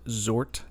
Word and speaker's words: Czort: I pronounce it Czort because I'm assuming that the C is silent. It's C-Z-O-R-T.